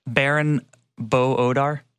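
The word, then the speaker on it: Baran bo Odar: Baron Bo-Odar, that's how you pronounce it, but the creator of Dark.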